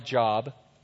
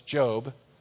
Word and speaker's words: Job: It's spelled like Job, but it's Job.